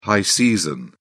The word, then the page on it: high season: British English